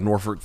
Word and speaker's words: Norfolk: this top prospect out of Norfolk, Virginia, which, by the way, they pronounce Norfolk, which I fucking hate.